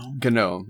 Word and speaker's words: GNOME: GNOME is the proper way of pronouncing it.